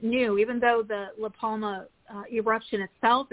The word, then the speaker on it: La Palma: Palma? What's the name of that volcano? – Yeah, La Palma.